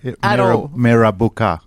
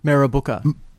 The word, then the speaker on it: Mirrabooka: What was it like to grow up in Mirrabooka? Am I saying that right? – No. – No. – You're not saying it right. Mirrabooka. – Mirrabooka. – Bingo.